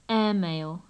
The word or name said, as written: airmail